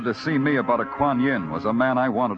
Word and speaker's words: Kuan Yin: K-U-A-N-Y-I... – Kuan Yin? – Yeah, Danny, that's it.